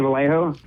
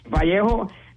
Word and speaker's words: Vallejo: Well, dude, it's like, you know, I was telling people, it's, you know, for mostly our fan base and demographic, it's A.J. Vallejo. You know, for the Latinos, it's Vallejo. And then if you're from Alabama, it's Valley Joe.